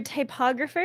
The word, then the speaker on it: typographer: Your typographer is the person. Typographer. – That's how you pronounce it.